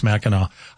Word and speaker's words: Mackinac: And I think with the way it's spelled, I think if you're not from there, that's a, you're going to mispronounce that. Mackinac, right? It's not, I know. But if you're from Michigan area, you're like, oh, don't say it that way. It's Mackinac. Highway 101, Washington to California.